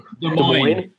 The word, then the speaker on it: Des Moines: Des Moines. There's no S on the end. – Yeah, there's no S. – There's an S in there. – Don't pronounce the S.